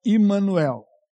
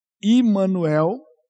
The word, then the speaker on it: Immanuel: aquilo ali que você está vendo, ó, Emmanuel, ali embaixo está vendo entre os dois raminhos, tem a palavra hebraica ali, a pronúncia é Immanuel. Immanuel, que literalmente é um nome composto de uma preposição, um pronome pessoal e um substantivo.